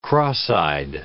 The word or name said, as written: cross-eyed